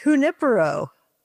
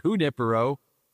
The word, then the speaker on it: Junipero: Shore Shifters, if you're down in Long Beach, also tomorrow morning, Shore Shifters Cars and Coffee is at, now I say Wanipero Beach. What is the name? How do you say that name? – Well, it's Junipero. – Okay, Junipero. All right, beach in Long Beach from 8 to 10 a.m.